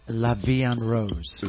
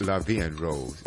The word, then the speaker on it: La Vie en Rose: Oh, how do we pronounce this, Professor? – La Vie en Rose. – La Vie en Rose. Yep.